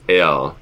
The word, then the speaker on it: är: it is är, and it's spelled A with an umlaut, so little dots, R, which would be A-E-R if you were to do an umlaut without the little dots.